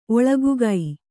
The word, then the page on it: ಒಳಗುಗೈ: oḷagugai